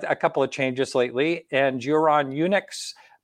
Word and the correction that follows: UNX: No, it's actually UNX. – UNX. – Yeah, okay. just the letters UNX, which really kind of stands for unexplained.